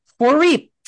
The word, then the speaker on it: Froriep: Froriep.